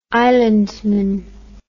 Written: islandman

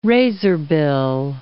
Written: razorbill

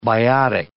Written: biotic

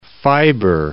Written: fibre

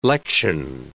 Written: lection

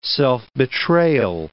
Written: self-betrayal

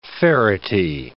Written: ferity

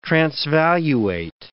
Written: transvaluate

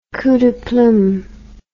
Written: coup de plume

